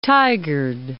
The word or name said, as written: Tigard